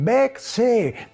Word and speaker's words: Berceuse: Muy bien, Berce, muy bien, Berce, palabra que se pronuncia más o menos así, pero que se escribe Berceuse.